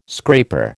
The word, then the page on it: scraper: American English